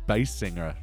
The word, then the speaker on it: Basinger: It's actually pronounced as Basinger. – Yeah. Right? – That's how she says it herself. – Alto, tenor, Basinger.